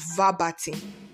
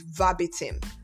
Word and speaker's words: verbatim: And the word verbatim, some people pronounce it as verbatim. But the real pronunciation of that word is verbatim. Verbatim, as in verbatim, verbatim. That's how it should sound.